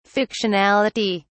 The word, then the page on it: fictionality: British English